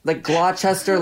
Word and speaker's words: Gloucester: There's like silent W's. There's like Gloucester, Leicester, like it's all Gloucester, Leicester, Ips. There's silent syllables.